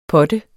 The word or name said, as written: potte